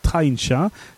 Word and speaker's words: Traincha: Elle s'appelle donc Trencha, ça s'écrit T-R-A-I-N-C-H-A,